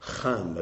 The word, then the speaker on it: Ham: Ham is really how it's pronounced.